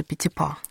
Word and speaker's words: Петипа: Непривычное ударение в имени Мариуса Петипа. – Ну, французское ударение на последний слог.